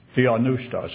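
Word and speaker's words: theopneustos: It's theonoustos. That's the correct pronunciation of the combination of those two words as it's used, where it's translated, all Scripture is given by inspiration of God.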